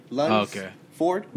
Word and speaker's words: Lunsford: Yeah, L-U-N-S-F-O-R-D. Lungs Ford.